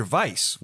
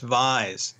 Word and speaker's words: Weiss: The name is actually pronounced Vise. – Like a Z sound.